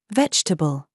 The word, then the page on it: VERVE: British English